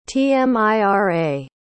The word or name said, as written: tmira